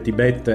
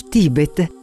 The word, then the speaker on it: Tibet: Come ci ha detto Pierpaolo, il Tibet e non il Tibet, anche se sarà difficile dirlo con l'accento nel posto giusto per tutto il viaggio, è un luogo magico, mitico, ma soprattutto è un luogo di resistenza.